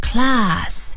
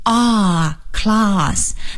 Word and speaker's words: class: 上课这个课，或者是也可以做班级来讲，是 class, C-L-A-S-S, class, class。那么这个啊，英式英语的读法呢，它是 A 读成啊，那么在这里的 class 这个 C-L-A-S-S 中间的 A 读成 R class。